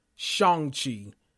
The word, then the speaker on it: Shang-Chi: Let me start off by saying in the movie, they pronounce it Shang-Chi. And so I should be saying Shang-Chi. You know, they make it a deal to say his real name. Well, his name in America, like his alias was Sean. And so he barely changed it from Shang-Chi to Sean. But I've been saying Shang-Chi along with everybody else for so long, but I need to be a lot more conscious about pronouncing it correctly.